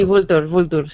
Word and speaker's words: Vulture: Sí, Vulture, sí.